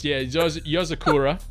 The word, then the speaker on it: Yozakura: Yozuka. – I'm gonna give that to you. It's Yozakura.